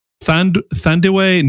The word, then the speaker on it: Thandiwe: As well as Thandiwe Newton, is that how you pronounce her name? – Yeah.